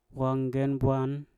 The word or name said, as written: wangen bwan